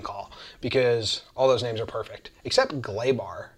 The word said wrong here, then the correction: Gleyber: Gleyber. I've read that it's pronounced Gleyber.